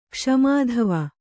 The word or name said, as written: ಕ್ಷ್ಮಾಧವ